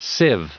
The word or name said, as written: sieve